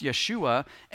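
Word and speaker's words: Yeshua (יֵשׁוּעַ): The name Jesus was originally pronounced Yeshua, and it means Yahweh, which is God's Old Testament name.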